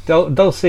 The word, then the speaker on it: Dulce: So he was involved with building a secret underground base in, is it pronounced Dulce? – Dulce, New Mexico, and for the military.